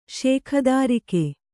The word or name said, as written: ಶೇಖದಾರಿಕೆ